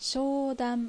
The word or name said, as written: Shoudan